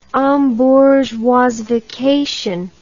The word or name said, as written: embourgeoisification